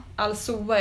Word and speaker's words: Alcoa: Det var ett bolag som heter Alcoa, Alsoa är nu uttalas. Och det är i alla fall ett gammalt, typ hundraårigt aluminiumbolag som finns i USA.